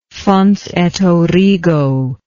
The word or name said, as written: fons et origo